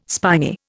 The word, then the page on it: spiny: Indian